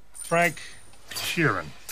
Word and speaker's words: Frank Sheeran: pantalla completa Frank Sheeran.